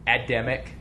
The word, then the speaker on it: Adamic: Adamic. So, A? – Yeah. – How do you spell it? – A-D. – Oh, gotcha. – Yeah, like Adam.